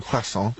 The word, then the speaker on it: croissant: When I was using the correct French pronunciation, croissant.